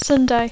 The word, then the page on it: Sunday: English